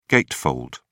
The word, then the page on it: gatefold: British English